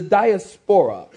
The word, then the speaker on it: diaspora: It's the diaspora.